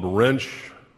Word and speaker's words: Wrench: For purposes of introduction, my name is Bob Wrench. Last name is pronounced like the tool.